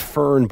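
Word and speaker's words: Föhn: It's pronounced Fern, but it's spelled in a German way, where these storms will come in, and then the danger with these storms is if you're climbing, you get stuck up there.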